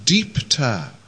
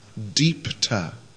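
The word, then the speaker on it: Dipta: Second one, blazing, Jwalit, J-V-A-L-I-T, short I, Jwalit. And shining is Deepta, D long I P T A, Deepta, D long I P T A, Deepta.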